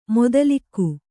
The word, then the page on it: ಮೂದಲಿಕೆ: kannada